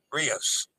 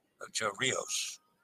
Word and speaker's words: Ocho Rios: Rios. Rhymes with dos. Rios. Ocho Rios.